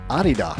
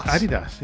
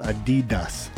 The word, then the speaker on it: Adidas: It's supposed to be Adidas. – Adidas, yeah. – And with a slight emphasis on the first syllable, not Adidas, stressing the second.